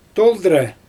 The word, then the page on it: toldIrɛ: Xyzyl